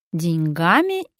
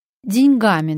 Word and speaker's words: деньгами: Давайте будем думать о том, как правильно распорядиться деньгами или деньгами. Деньгами, друзья, не деньгами.